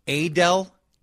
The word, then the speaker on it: Adele: Now somebody is pointing out, did she 5 years into superstardom, is she now calling herself Adele? – Adele is how we're pronouncing Adele.